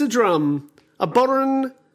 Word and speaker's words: bodhran: A boron is what type of instrument? – Oh, a boron. – Yeah. – Boron. – And it's spelt bodhran.